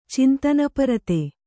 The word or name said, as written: ಚಿಂತನಪರತೆ